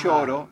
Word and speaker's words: Yoro: Y-O-R-O. Lloro.